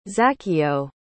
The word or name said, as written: zaccheo